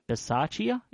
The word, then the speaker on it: Bisaccia: He took the opportunity, well, they took the formal opportunity to announce Greg Olsen, Paul Gunther, and Rich Bisaccia. Is that the correct pronunciation of that name? – That is. Well done.